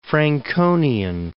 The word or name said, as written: Franconian